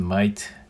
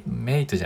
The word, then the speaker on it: mate: M-A-T-E で。はい。ノーボールズマイト、マイ、なんかね、発音も面白くて、マイ、メイトじゃなくてマイトみたいな感じで、グッドマイトみたいな感じなんですけど、はい。